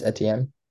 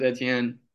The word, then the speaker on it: Etienne: Oh, Travis Etienne. – Yeah, Travis Etienne. He's against Baltimore defense, not that great of a rushing defense either. I think he'll have a big week. Travis Etienne out of Jacksonville.